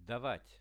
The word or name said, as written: davat